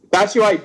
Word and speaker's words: Batshuayi: Bakayushi, how do you say his name? I'm so bad at his name. – Batshuayi? – Batshuayi, Batman, scored a late 90th minute equalizer for Valencia.